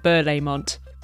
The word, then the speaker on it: Berlaymont: The Commission's headquarters, a massive, quite scary-looking building in central Brussels, which is called the Berlaymont. Did I pronounce that right? – I call it Berlaymont.